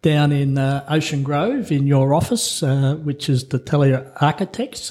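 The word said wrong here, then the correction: D'Atelier: Have I pronounced that correctly? – Pretty close. So D Atelier.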